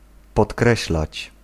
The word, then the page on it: souligner: France; /su.li.ɲe/